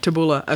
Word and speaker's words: tabbouleh: Tabbouleh.